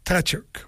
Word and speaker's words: Tkachuk: Tkachuk, oui, je le prononce comme ça.